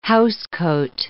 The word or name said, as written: housecoat